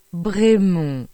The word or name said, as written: Bremont